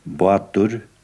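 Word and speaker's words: bátur: I sørlige, særlig i den sørlige halvdelen av landet Eh, der uttaler man eh, P som B og T som D og K som G og sånn noe. For eksempel eh, båt, den heter Batur i den sørlige delen av landet, mens lengre nordpå heter det Batur.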